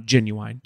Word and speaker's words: Ginuwine: Now, Cameo did go full throttle on Ginuwine. Did I say it right? – You said it right.